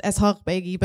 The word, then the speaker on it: Eshaghbeigi: If you're curious, it's pronounced Eshaghbeigi, but truthfully, I didn't really know that for most of my life.